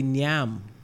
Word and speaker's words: nyam: Well, I know Jamaicans will say nyam. Like, they put an N to the front of it. – Now, that too is actually a more closer pronunciation to what is said in the Congo. Because they say nyam as well.